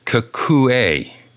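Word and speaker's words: Kakuei: I might be pronouncing his name wrong. Kakui? – I would think that's Kakue. Yeah, Kakue Tanaka.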